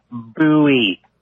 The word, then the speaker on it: Bowie: It's pronounced Bowie, you know, like the famed knife fighter, James Bowie, legendary defender of the Alamo, James Bowie, prominent slave trader, James Bowie.